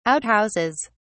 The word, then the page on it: outhouses: British English